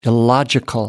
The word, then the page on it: illogical: American English